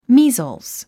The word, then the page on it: measles: míːzlz; ミィゾース